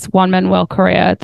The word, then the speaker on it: Juan Manuel Correa: Yeah, and because we did Zoe picked a learning moment for our last episode, which was Juan Manuel Correa, the F2 driver. Did I say his name correctly? – Yes.